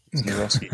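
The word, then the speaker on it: Nawrocki: Thank you, pronouncing his name wrong. Naroski. There we go.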